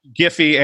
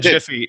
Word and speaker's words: Giphy: I pronounced it both Giphy and Jiffy.